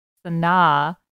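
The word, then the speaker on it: Sanaa: She has a pronunciation on her IMDb of her name, and it's Sana, quote, like Sinatra without the tra, end quote.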